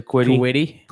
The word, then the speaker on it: Kwity: But no idea how to say this guy's first name. – I would say Quiddy. – Quiddy Pay. Yeah, Quiddy Pay. So he is spelled K-W-I-T-Y for anybody that's listening and not watching.